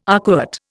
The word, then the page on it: arcuate: Indian